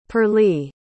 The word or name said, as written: pearley